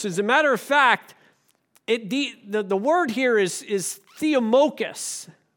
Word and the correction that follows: theomachos: Wait, is that, am I saying that right? Not good with Greek. Yeah, theomachus, machos or something. Theomachos, there you go.